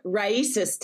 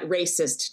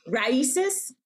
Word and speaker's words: RAICES: Be clear, Raices Texas, not Racist Texas. – Wow. – Be clear. – But no, right. But yeah, no. How do you pronounce it again? – Raices.